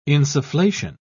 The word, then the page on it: insufflation: British English